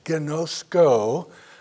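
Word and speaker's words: gnosko (γινώσκω): The first that we're most familiar with is pronounced gnosko, a Greek word for knowledge, and it means to know by experience.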